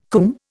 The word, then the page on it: coomb: Indian